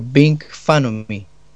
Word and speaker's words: Ving Phanomy: Eh, bueno, busquen en buscador como Bing Fanomy. Se escribe V-I-N-G P-H-A-N-O-M-Y. – Así directamente entonces, Bing Feinomi, eh, V-I-N-G P-H-A-N-O-M-Y, para quienes quieran encontrar a nuestros amigos de Bing Feinomi, que son una excelente banda de la cuarta región.